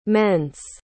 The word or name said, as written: mense